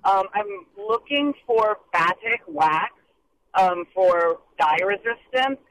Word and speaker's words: batik: B as in boy, A-T-I-K. Batik, I think, is how I've always heard it pronounced. – Batik, there we go. – Batik Wax.